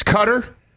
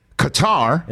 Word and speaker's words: Qatar: The multiple pronunciations of the host country. – Oh, yeah. – Oh, Qatar. – I've seen three different ones. – It's Qatar. Qatar.